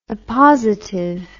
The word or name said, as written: oppositive